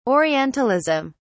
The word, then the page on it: orientalism: British English